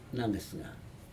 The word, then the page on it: nagesega: Aneityum